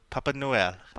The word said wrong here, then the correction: Papai Noel: Papai Noel.